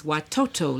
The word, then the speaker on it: watoto: The Swahili word for children is watoto. Let's hear you pronounce that.